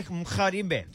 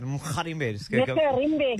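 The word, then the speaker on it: Mgarimbe: I can't pronounce just say Mkharimbe. Just like that. Mkharimbe.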